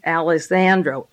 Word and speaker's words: Alejandro: Diaz Alejandro, A-L-E-J, the J is silent, Alejandro, A-L-E-J-A-N-D-R-O,